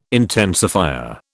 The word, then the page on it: intensifier: British English